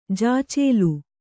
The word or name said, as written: ಜಾಚೇಲಿ